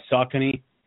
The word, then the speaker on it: Saucony: I'm about to try Saucony out, which have gotten very good.